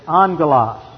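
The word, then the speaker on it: angelos (ἄγγελος): The word in the Greek is angelos. Whenever you have two gammas together for your Greek students, it's always pronounced like an NG.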